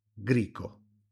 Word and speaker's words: grico: Pensate alle comunità grecofone dell'Aspromonte e del Salento, che forse parlano un greco, lo chiamano grico, si pronuncia grico, ininterrottamente dai tempi della Magna Grecia.